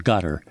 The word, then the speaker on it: Qatar: I had an old friend, elderly friend, who worked in the area who said that is actually how they pronounce it, gutter, not.